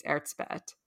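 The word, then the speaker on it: Erzsébet: And I'm using Anglicanized versions of her name because I believe her name's Erzbeth, but it's like Elizabeth.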